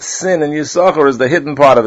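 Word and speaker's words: Yissachar: One is silent, and one is not. You don't say Yisachar, it's Yisachar.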